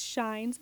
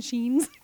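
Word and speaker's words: Schine: I think it's Shines. I keep wanting to say Kathleen Sheens because it rhymes, but I think it's Shines. The Grammarians,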